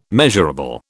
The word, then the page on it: measurable: British English